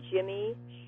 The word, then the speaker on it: Jymme: but I used to go by Jimmy Shore, and it's spelled J-Y-M-M-E, mm-hmm.